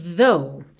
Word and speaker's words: though: E esse som tanto pode ser com ar, th, como na palavra think, como vocalizado, th, como na palavra though, aqui, ok? E depois do U, é mudo, então você pode ignorar aquele GH do final.